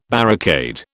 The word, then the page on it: barricade: British English